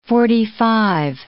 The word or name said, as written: forty-five